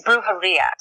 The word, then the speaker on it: Brujeria: It's Brujeria.